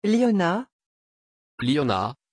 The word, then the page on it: Lyona: French